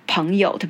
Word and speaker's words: 朋友: 哦，讲到朋友，对不起，我刚刚的发音应该是嗯，朋友。我发现很多台湾的人其实会念的是朋友。呃彭嗯的音哦，顺便跟大家说一下，因为发音其实精准还是对你说各种各式的语言会比较好啦，吼，所以我还是有的时候还是会呃跟大家提醒一下。